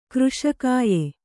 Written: ಕೃಶಕಾಯ

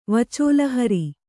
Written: ವಚೋಲಹರಿ

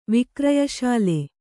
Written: ವಿಕ್ರಯಶಾಲೆ

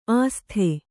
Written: ಆಸ್ಥೆ

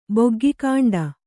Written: ಬೊಗ್ಗಿಕಾಂಡ

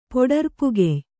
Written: ಪೊಡರ್ಪುಗೆಯ್

